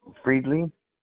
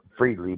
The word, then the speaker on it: Friedley: It's Jeffrey Friedley, J-E-F-F-E-R-Y, and then Friedley spelled like fried chicken, fried, and then just put L-E-Y on there. It's pronounced Friedley, but spelled Friedley. – So again, F? – F-R-I-E-D-L-E-Y.